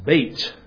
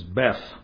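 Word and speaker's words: bait (בית): It's pronounced bait, like mate. Even though looking at it, you'd think it was Beth.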